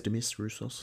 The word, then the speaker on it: Demis Roussos: I think that's sort of, yeah, that's the best way to sum it up, is kind of Abigail's Party people listen to Demis Roussos, or how you pronounce it, never quite sure whether it's... – Demis Roussos. – Roussos, yes, and Brotherhood of Man and all that kind of stuff.